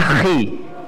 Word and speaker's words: chi: Kalau X bacanya apa? Di huruf Yunani bacanya chi. Bacanya apa? Chi.